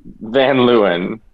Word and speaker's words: Van Leeuwen: Van Leeuwen.